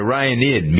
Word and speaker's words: Orionid: It's the Orionid meteor shower. I think that's how you pronounce it, right? – Mm-hmm.